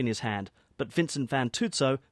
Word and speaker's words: Fantuzzo: This year, we'd like to award the Packing Room Prize on behalf of the Packers and me to Vincent Fantuzzo for his portrait of Matt Moran. And they are here. – Slight pronunciation. Vincent Fantuzzo, not Fantuzzo. Look at him.